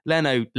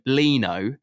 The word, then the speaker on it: Leno: So he calls Leno Lino. How have you interpreted Leno is Lino, and he called Vinicius, he calls him Vinicus.